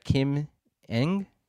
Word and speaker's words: Kim Ng: The general manager for the Marlins, I'm not going to pronounce her name right, Kim Ng? – You got it.